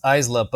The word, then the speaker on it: Aisla: Okay, already I hate it because it's pronounced Aisla, but like, it sounds weird. It should be Isla. Like, if you pronounce that in English, that name is pronounced Isla. The S is silent.